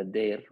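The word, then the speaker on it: Deyr: And the other season is, that one I'm not going to be able to do, but Dyer. Oh, close enough. – What is the correct pronunciations for the two rain seasons? – The Gu, which is the spring, the Dyer, which is fall.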